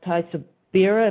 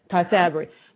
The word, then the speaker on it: Tysabri: And then I know they have Tysabira, if I'm pronouncing that correctly. – Mm-hmm. – Now, that was very controversial. Say it again. – Tysabri.